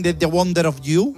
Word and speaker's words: The Wonder of You: Bien, la canción se llama, a ver si lo digo bien, The Wonder of You.